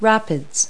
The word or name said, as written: rapids